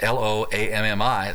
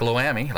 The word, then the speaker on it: Lo-Ammi: And then Lo-Ammi, probably pronouncing that wrong, but it's L-O-A-M-M-I. That sounds like Lo-Ammi, like Miami, only it's Lo-Ammi, which means not my people.